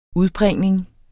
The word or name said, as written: udbringning